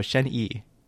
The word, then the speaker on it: Shen Yi: Oh, that's how you pronounce Y I in like the Chinese language is like E. – Okay. – Like double E, I guess. So Shen Yi is the support's name.